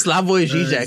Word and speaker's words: Slavoj Žižek: It's Slavoj Žižek.